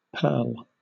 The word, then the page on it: purl: British English